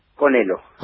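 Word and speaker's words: Conhelo: Que esa H que hay en el medio cambiara la pronunciación, así que fácil. – Sí, no, Conelo. – No. Se pronuncia Conelo, así nomás.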